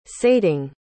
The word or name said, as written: sating